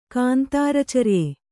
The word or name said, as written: ಕಾಂತಾರಚರ್ಯೆ